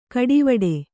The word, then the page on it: ಕಡೆವೀಡು: kaḍevīḍu